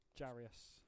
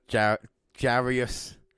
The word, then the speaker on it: Jarrius: And that, of course, is the Warrior War for JJ. Jarrius. Jarrius. Yeah, that was uh, Jarrius. Sorry, I, really have trouble pronouncing because it's a double J with the the day JJ Jabris that, but it's Jarrius Robinson that uh, got the Warrior Award there.